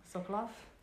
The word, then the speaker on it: Sokolov: It's based on interviews that were conducted with Holocaust survivor in Auschwitz, Birkenau tattooist Ludwig Solvecq, if I'm pronouncing that correctly. Yeah, this one right here. – Sokolov? – Sounds good.